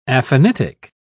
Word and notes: aphanitic: British English

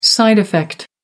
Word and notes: side effect: American English

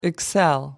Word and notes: excel: American English

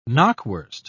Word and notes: knackwurst: British English

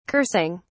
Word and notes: cursing: British English

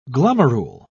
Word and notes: glomerule: British English

